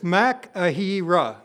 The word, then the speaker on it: machaira: The Greek word here is makahira. It's spelled, if you're taking notes, M-A-C-H-A-I-R-A.